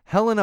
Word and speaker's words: Helena: Uh, some of the actors that will be in in the series are Mark Hamill, yes, uh, uh, Taron Egerton, uh, Helen Helen, I always say her name wrong, Helena Bonham Carter.